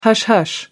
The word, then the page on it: hush-hush: American English